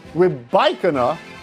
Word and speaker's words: Rybakina: Anz Jabeur and Elena Rybakina will face each other in the Wimbledon women's final. Are you intrigued by that? How is my pronunciation? – Excellent, A, but B, we're a big Rybakina household because my college freshman year roommate was from Kazakhstan.